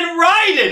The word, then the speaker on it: Raiden: He said Raiden! It's pronounced Raiden!